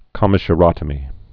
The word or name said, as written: commissurotomy